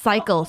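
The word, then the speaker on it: Psycle: A spin class that was Coachella themed at Cycle, spelled Pacycle. And wait, spell it? Spell it? – Pacycle. It's Pscycle. Cycle.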